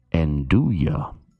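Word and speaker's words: nduja: The correct pronunciation is Andouille.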